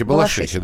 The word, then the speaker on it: Балашихи: Правильно говорить? Балашихи? – Балашихи, или... Балашихи, Балашихи, да.